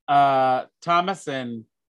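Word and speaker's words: Thomasin: But when Thomason? Am I saying it right? – Yeah.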